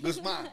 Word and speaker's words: Guzman: Did I say it right? – Yes, you did. – Okay. I try to say it like American. – No. Guzman. – Guzman. Okay. Okay.